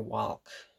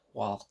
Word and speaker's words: walk: He actually pronounces the L. And I don't know a single person, I don't know anybody in the history of existence to actually say, I'm going to go for a walk with the L pronounced.